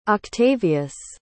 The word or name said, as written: octavious